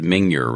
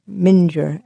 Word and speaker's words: Mingyur: Do you pronounce it Minjar Rinpoche? – Yeah. – Okay. So I've been mispronouncing it this whole time. – What do you say? – I thought it was Mingyur Rinpoche. – Minjar, M-I-N-G-Y, Minjar Rinpoche.